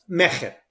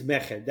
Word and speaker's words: Mejer: So Mejer. – Mejer. – The right. – Phonetically accurate is Mejer. That's true in Hebrew as it is in Spanish. It's a guttural sound.